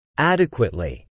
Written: adequately

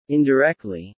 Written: indirectly

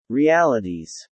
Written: realities

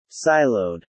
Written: siloed

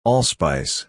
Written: allspice

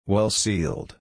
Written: well-sealed

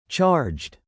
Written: charged

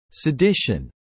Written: sedition

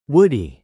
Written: woody

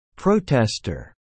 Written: protestor

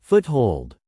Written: foothold

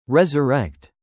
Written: resurrect

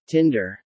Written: tinder

